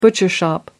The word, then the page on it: bunny rabbit: British English